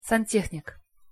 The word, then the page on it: santehnik: Russian